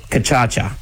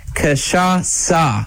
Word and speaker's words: Cachaça: An uncultured person would say Kachacha, Michael Lane, Chris Barnum, number 3 in race number 2. But it is actually pronounced Cachaça. It is a Brazilian liqueur, and its name...